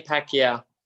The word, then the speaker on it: Pacquiao: Manny Pacquiao.